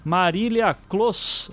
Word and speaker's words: Marília Closs: E além do Carapanã, que há muito tempo não vim aqui, nós temos uma convidada especial aqui também, estreando aqui na Podosfera, Marília Clos. É, eu falei certo, Marília? – Falou, falou certinho, dos poucos que acerta meu nome.